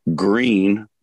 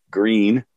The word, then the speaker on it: Gruene: I'm playing all the time, like I say, four times a week all around New Braunfels, Green, which is a German word. It's spelled G-R-U-E-N-E, Green, Texas, very popular place.